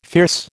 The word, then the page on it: fierce: American English